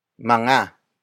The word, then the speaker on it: mga: But first, we have mga. Mga. Mga. Mga is spelled as M-G-A.